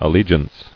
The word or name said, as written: Allegiant